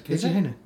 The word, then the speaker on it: Keiji Haino: It's Keiji Haino, which I'm pronouncing incorrectly, obviously. – No, that is correct. Keiji Haino.